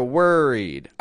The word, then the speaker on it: worried: Now, on that word there, worried, I just want to point out, notice I'm not pronouncing the O in worried like worried. You know what I mean? I'm getting it like an er.